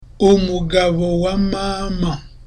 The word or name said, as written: umugabo wa mama